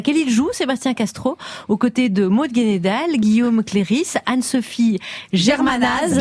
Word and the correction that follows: Le Guénédal: Et c'est Maud Le Guénédal, je me permets. – Pardon. Mais pardon. Le Guénédal.